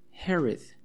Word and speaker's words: Herod: Se escribe H-E-R-O-D, H-E-R-O-D, Herod. Se escribe Herod, Herod con H, pero se pronuncia Herod, ¿ok?